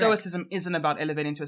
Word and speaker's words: stoicism: Stoicism.